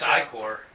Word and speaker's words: Ichor: I'm not sure the pronunciation. It's Ichor. I-C-H-O-R. – I think it's I-Core. So here they're giving some I-Core is like a type of punk.